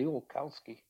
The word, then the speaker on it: Ziolkowski: It's, you know, and I have a couple times I have said, you know, if you just pronounce the four syllables, the Ziolkowski, it comes out, you know.